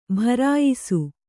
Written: ಭರಾಯಿಸು

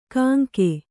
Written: ಕಾಂಕೆ

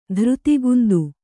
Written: ಧೃತಿಗುಂದು

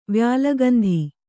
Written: ವ್ಯಾಲಗಂಧಿ

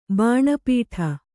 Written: ಬಾಣಪೀಠ

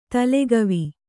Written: ತಲೆಗವಿ

